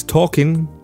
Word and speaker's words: talking: This is a pronunciation issue. Walking. Don't say that little. You don't need to. Walking. Similar ones. Talking. No L pronounced in it. It's written, yes. You write the L. The consonant is there, but not pronounced.